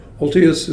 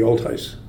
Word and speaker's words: Olthuis: Ultius is it's a Dutch name. It's actually the Canadian pronunciation. In Dutch, it would be Ultius.